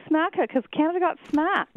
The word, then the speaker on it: USMCA: Well, first I'll help you with how to pronounce it. It's USMCA.